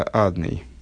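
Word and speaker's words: Адонай: Вместо Авая читается Адный.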